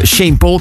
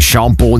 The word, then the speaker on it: Sean Paul: Amaika, Jamaica met onze Shane Paul. Ik heb al luisteraars gehad doorheen de zomer die soms insturen van Wouter, kan je nu Sean Paul niet uitspreken? Of zeg je altijd Shane Paul?